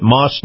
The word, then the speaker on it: Maus: Now, Moss is spelled M-A-U-S, Nissan.